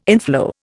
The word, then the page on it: inflow: Indian